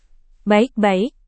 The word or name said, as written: baik-baik